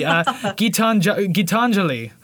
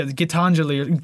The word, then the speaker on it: Gitanjali: I had to look up how to say this properly. Gitanjali. Which I think he even says, you can quietly hear him say it in the song, but yeah, Gitanjali. Gitanjali. I even wrote it out like phonetically.